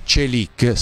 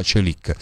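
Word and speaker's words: Çelik: E io gli ho detto: attenzione, non fare il tifoso medio, perché Celic, si pronuncia così, ve l'ho fatto ascoltare prima, ce l'ha ancora.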